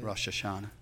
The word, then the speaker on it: Rosh Hashanah: Rosh Hashanah.